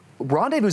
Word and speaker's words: rendezvous: and so um, before you know it, though, rendezvous is a weird word to spell. There's a silent Z that nobody knows about.